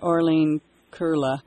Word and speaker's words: Arlene Curla: Well, I've got Orlene Curla. Is that, did I say it right then? – Yes, you did. – Ah, I had to do it phonetically to finally figure it out.